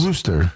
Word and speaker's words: Worcester: A woman ran away from a man who appeared to propose to her at a Worcester Red Sox game on Thursday. But yeah, I enjoy watching stuff like this because Worcester. It's kind of cringy. – Is it like Massachusetts? – Worcester.